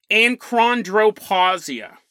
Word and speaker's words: achondroplasia: But between the ages of 5 and 18, you can take a daily injection called Voxzogo, and this treats acroplasia. This treats achondroplasia.